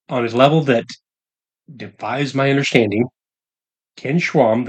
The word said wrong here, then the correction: Schramm: the godfather of the book. – And Schramm. – Schramm. Yeah. I've been saying his name wrong since I got the book.